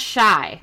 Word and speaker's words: Chi: Oh, how do you, it's pronounced Shy.